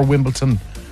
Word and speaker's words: Wimbledon: Is it Wimbledon or Wimbledon? – It's Wimbledon. Dun. Dun. – Dun. – Yeah. Dun. No, not the ton. Dun. Wimbledon. – That's the place, Wimbledon.